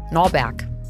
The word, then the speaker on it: Norberg: Er nimmt ihren Namen Norbert. Norberg. Norbert. Norberg. Du kannst das nicht immer falsch aussprechen.